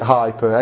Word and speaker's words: hyper: Well, we pronounce it hyper, H-Y-P-E-R.